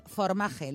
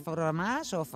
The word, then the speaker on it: Formaje: Formaje, no lo he dicho mal, ¿eh? Ya sé que en francés es formage, pero no, ellos se llaman formaje. Porque, bueno, pues es la versión española.